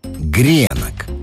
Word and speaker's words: гренок: гренок и никакого женского рода. Все это разноголосие можно понять, если проследить историю слова. На первый взгляд может показаться, что существительное «гренок» с ударением на последний слог русский язык позаимствовал где-то в Европе.